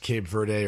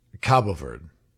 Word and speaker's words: Cabo Verde: I'm seeing, by the way, it's pronounced both ways. It's pronounced Cape Verde or Cape Verde. But the Portuguese pronunciation is Cabo Verde.